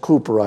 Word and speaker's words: Cowper: William Cooper, spelled Cowper, pronounced Cooper, I believe.